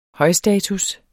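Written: højstatus

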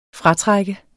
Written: FRATRUKKEN

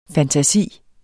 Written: fantasi

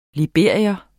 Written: LIBERIERNE

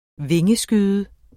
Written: vingeskyde

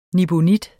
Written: nibenit

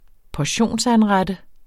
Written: portionsanrette

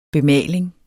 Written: bemaling

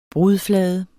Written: brudflade

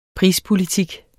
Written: prispolitik

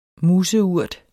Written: museurt

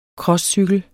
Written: crosscykel

